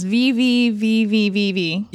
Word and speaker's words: VVVVVV: I don't know how to pronounce this. V-V-V-V-V-V. – Yeah. – I think you did it. Yeah. – That's right. It's six capital Vs, yeah.